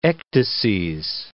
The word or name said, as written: ecdyses